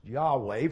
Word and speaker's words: Yahweh: And we think this self-given name is pronounced Yahweh, from which we get our word Jehovah.